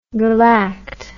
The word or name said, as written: galact